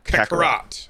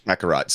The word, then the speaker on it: Kakarot: Kakarot. – All right. – Yeah, Kakarot.